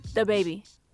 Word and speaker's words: DaBaby: DaBaby. – It was pretty good. – I mean, she put a lot less emphasis on duh.